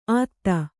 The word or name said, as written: ಆತ್ತ